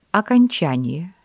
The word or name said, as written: окончание